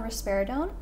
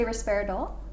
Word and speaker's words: risperidone: Risperidone. Or you can say risperidol. – Risperidol, risperidone. – Risperidone. Risperidone. Don't, but with an N.